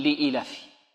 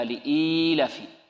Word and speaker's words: لإيلاف: يقول لإيلاف. لإيلاف قريش وهذا خطأ، بل لابد من مد الكسرة التي على الهمزة وإشباعها حتى يتولد منها ياء، هكذا لإيلاف. لإيلاف أو أن ننطق بياء مدية بعد الهمزة هكذا لإيلاف، لابد هذا يضغطها القارئ قليلا أو يعمل لسانه فيها كي يتمكن من نطق الياء بعد الهمزة، الياء المدية هكذا لإيلاف.